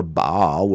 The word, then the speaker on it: Baal: Now, when it says Israel was joined to Baal, or Baal would be a more correct pronunciation of the Hebrew there, they were joined to an idol.